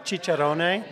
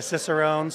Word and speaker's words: cicerone: And I think descriptions you can give in beer menus and things, having the equivalent of a, I guess it's called a, I prefer the Italian pronunciation for chicharrone. – Uh-huh. – You can be a cicerone, sommelier, whatever term you want.